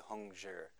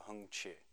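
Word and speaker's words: Hung-chih: Hung Chi, I've been pronouncing it wrong. I say Hung Zhi. I think it's closer to Hung Chi. somebody who can pronounce Chinese can correct me later.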